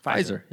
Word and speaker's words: Pfizer: Pfizer.